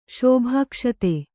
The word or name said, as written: ಶೋಭಾಕ್ಷತೆ